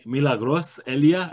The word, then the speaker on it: Milagros Elia: I'm joined by Milagros Elia.